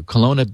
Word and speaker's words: Kelowna: It's a silent W. Kelowna, B.C., Canada.